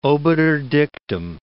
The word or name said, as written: obiter dictum